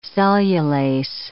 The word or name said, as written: cellulase